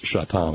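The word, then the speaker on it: Šatan: Pronounced Shatan, of course. That joke is big in Buffalo. Miroslav Shatan, former member of the Buffalo Sabres.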